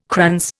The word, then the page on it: krans: Indian